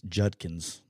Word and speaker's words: Judkins: Quinshawn Junkins, Judkins, I get killed for pronouncing Quinshawn Judkins.